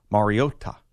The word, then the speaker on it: Mariota: Well, he pronounces it the way that Mariota pronounces it, which is Mariota.